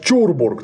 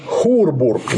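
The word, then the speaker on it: Churburg: Обращаю внимание, пишется он через CH. CH-Урбург. Обычно его имеют обыкновение произносить как Чурбург. Так вот, он находился не в Ист-Энде, он находился в Южном Тироле. Это Хурбург, никак по-другому.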